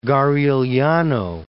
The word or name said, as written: Garigliano